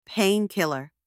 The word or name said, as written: pain killer